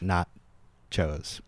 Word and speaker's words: Gnotchos: Yeah, they spell that G-N-O-T-C-H-O-S, like not chos.